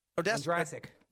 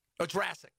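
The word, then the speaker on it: Ondrasik: Is it John Odeskett? – Odrasik. – What is it? Odrasik today.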